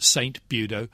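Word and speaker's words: St. Budeaux: And you also get St. Budo, only originally it was B-U-D-O, and we French... Frenchified it to become B-U-D-E-A-U-X. – Very good. – But it's the same pronunciation, of course.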